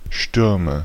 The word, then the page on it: Stürme: IPA: /ˈʃtʏʁmə/